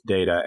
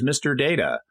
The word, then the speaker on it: Data: It gets pronounced, the word in real life gets pronounced both Data and Data. – Yes. – And they hadn't established how the character's name was going to be pronounced. And Brent Spiner, the actor, had kind of been assuming it was Data. And then Patrick Stewart walks onto the bridge and says, Mr. Data. And it became Data from that moment forward.